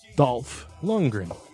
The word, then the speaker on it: Dolph Lundgren: What's how do you say it? Say it one more time. – Dolph. Lundgren.